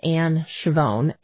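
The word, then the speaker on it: Ann Schiavone: And if you search under faculty and staff, my name is Anne Chavone, A-N-N, and the last name is spelled S-C-H-I-A, V as in Victor, O-N-E.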